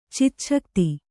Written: ಚಿಚ್ಛಕ್ತಿ

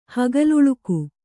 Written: ಹಗಲುಳುಕು

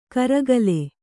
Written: ಕರಿಗಾಲ

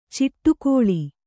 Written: ಚಿಟ್ಟುಕೋಳಿ

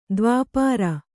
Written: ದೈವಾಪರೆ

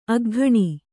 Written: ಅಗ್ಗಿಣಿ

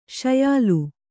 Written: ಶ್ಯಾಲ

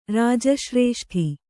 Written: ರಾಜಶ್ರೇಷ್ಠಿ

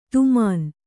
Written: ಧಮನಿ

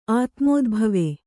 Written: ಆತ್ಮೋದ್ಭವ

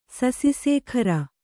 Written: ಸಸಿಸೇಖರ

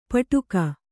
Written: ಪಾಟಕ

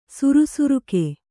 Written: ಸುರಸುರಿಕೆ

